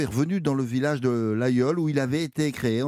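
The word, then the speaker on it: Laguiole: Euh, alors, euh, Gavin, l'Aïol ça se situe où sur la carte Alors, nous n'oublions pas que ça s'écrit L-A-G-U-I-O-L-E. J'aime bien cette prononciation.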